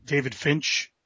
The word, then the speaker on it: David Finch: Like I saw David Finch.